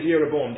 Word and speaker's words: Jeroboam: That Solomon has a commander, and his commander's name is Jeroboam. Do you see that? That's with the J. We say it like a Y.